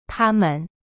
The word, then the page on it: 他们: ta-men